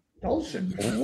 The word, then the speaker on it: Dulcich: And then up one spot up from him is Greg Dulcich. Did I say it right? – Yes. – Dulcich.